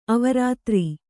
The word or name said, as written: ಅವರಾತ್ರಿ